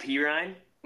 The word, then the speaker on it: Perine: Perine, which I'm shocked that that's actually how you pronounce it.